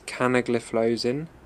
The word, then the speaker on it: canagliflozin: The first is dapagliflozin, the second is canagliflozin, and the third is empagliflozin.